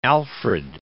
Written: alfred

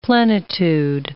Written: plenitude

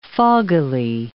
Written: foggily

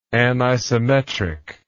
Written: anisometric

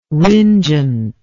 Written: ringent

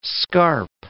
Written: scarp

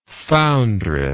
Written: foundress

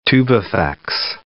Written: tubifex worm

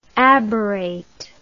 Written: aberrate

